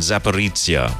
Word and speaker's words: Zaporizhia: And the regions are the Lugansk, Donetsk, Kherson and Zaporizhia regions. How did you say that? – Yeah. You got pretty close. – I think I actually nailed it. – Nailed all of them.